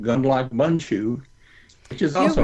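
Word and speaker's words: Gundlach Bundschu: You just string together four short words, gun, lock, bun, and shoe. Gun, lock, bun, shoe.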